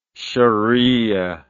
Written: shariah

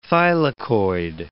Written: thylakoid

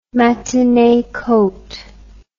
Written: matinee coat

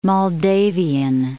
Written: Moldavian

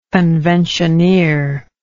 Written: conventioneer

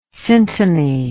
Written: syntony

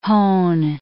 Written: hone

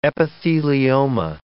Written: epithelioma

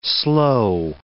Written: slow

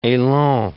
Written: elan

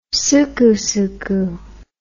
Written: sucusucu

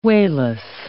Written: wayless

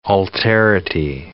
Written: alterity

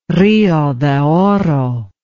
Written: Rio de Oro

